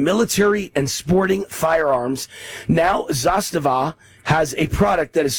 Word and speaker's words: Zastava: Zastava.